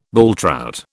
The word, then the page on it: bulltrout: British English